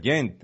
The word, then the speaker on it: Gent: Lo que sí me sorprende es que el Lyon, pues, no haya podido capitalizar mejor contra el Gent, a pesar de que, o Gant, creo que se pronuncia, el Gant.